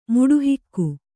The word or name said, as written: ಮುಡುಹಿಕ್ಕು